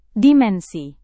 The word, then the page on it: dimensi: indonesian